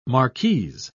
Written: marquise

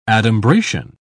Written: adumbration

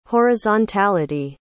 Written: horizontality

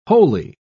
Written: holey

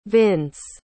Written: vince

